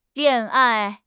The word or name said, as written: lian4 ai4